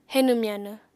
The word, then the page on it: henw-myanw: apatani